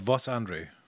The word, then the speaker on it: Vaas-André: How do you say your last name correctly? – Julien Vaas-André. – Vaas-André. – Yeah, or it depends. My mother and my father even pronounce it differently. – Oh, really? – In German. Uh-huh. So there's millions of ways, at least three different ways.